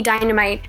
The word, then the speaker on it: Dynamyte: It's at call me Dynamyte.